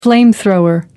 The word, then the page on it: flamethrower: American English